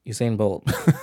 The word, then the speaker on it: Usain: Usain Bolt.